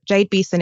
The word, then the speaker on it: Jade Beason: So Jade Beason is spelled J A D E B E A S O N.